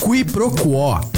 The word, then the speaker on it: Quiproquó: Quiproquó. Q-U-I-P-R-O-Q-U-O com acento agudo.